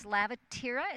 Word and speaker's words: Lavatera: and it's Lavatera. Is that how Correct. – You pronounce it? – Mm-hmm. – Lavatera.